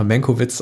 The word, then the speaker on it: Mankiewicz: In der heutigen Folge sprechen wir über Mank, der, wie mein Kollege Arne Wielander schon angemerkt hat, angemerkt hat, im Deutschen immer Menkowitz ausgesprochen wird, was auch sehr lustig ist.